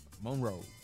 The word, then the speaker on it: Monroe: Monroe. – ¿Eh? ¿Y cómo yo dije? – Monroe. Tú tienes que decirlo lento, Monroe.